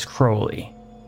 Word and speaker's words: Crowley: And last time I checked, holy doesn't rhyme with Crowley. Does rhyme with Crowley, though. But as I said, pronunciations are varied when saying the name, and it's not really a big deal. In any case, I'm just going to stick to calling him the way I like to pronounce it, and I think is the correct way to pronounce it, which is Crowley.